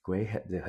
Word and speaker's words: Graham: 这个台湾政策法是由我们 Menendez 跟 Graham Graham 这个呃 Graham 对，不，这个 H 不发音哦。 Graham，很多人说 Graham Graham，他是南卡的这个议员，也就是之前来过台湾的，为这个波音啊飞机说话的这个南卡的议员哦。